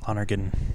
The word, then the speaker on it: Lonergan: Lonergan.